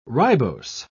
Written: ribose

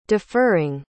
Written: deferring

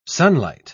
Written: sunlight

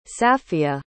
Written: safia

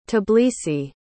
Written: tbilisi

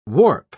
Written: warp

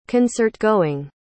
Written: concertgoing

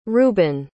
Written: rubin

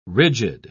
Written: rigid

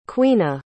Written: queena

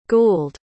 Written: gould

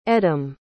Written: edem

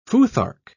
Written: futhark

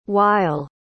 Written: wyle

